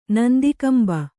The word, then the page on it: ನಂದಿಕಂಬ: nandi kamba